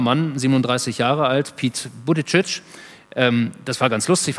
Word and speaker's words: Buttigieg: Und dann sagte er, ja, Buttigieg, aber äh, Sie können mich auch einfach äh, Bürgermeister Pete nennen.